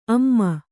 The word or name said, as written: ಅಮ